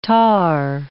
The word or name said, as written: tahr